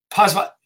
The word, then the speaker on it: Poswolski: You are live with Josh Tickell and Adam Smiley Poswolski.